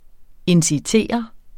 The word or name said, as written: incitere